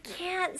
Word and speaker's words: can't: 네, 여기서 발음 조심하셔야 되는 거는 I can 할 수 있다. 그리고 I can't 명확히 이거를 발음을 해주셔야 돼요. 네, can't 라고 할 때는 모음을 좀 더 길게 해주시고 T까지 꼭 발음해 주셔야 됩니다.